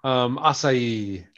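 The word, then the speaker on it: açaí: It is pronounced acai. I know because it's Japanese. – Portuguese. – Oh, Portuguese. – Well, Brazilian. – Yeah. Okay. – You know, Brazilian Portuguese.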